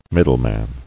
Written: Middleman

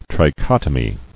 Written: Trichotomous

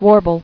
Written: Warble

